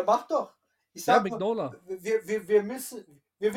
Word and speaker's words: Mignola: Ich sage Mignola, es gibt Leute, die sagen Mignolia. Ne? Äh, wie sagst du es, AJ? – Lustigerweise sage ich Minola.